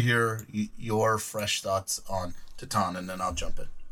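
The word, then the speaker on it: Titane: How's it pronounced? – Titan. – Titan. Okay.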